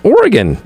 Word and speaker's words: Oregon: So I'm saying it wrong. It's Oregon.